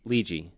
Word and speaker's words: Lidji: Lidji. – Oh, Lidji. – Yeah. – Okay. L-I-D-J-I.